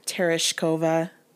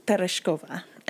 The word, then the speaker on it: Tereshkova: Tereshkova. Like, come on. It's more fun. Tereshkova. And that's what the Russian girl on one of the podcasts pronounced it.